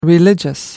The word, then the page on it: religious: British English